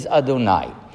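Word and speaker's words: Adonai (אֲדֹנָי): But Chazal told us that the proper pronunciation that we associate with that name, and I'll repeat it for the purposes of learning, is Adonai, from which the word Adon, of course, is the root, master.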